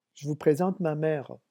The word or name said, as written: je vous présente